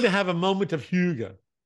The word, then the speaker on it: hygge: And it's pronounced, thank you for writing down the pronunciation. Hygge. Hygge.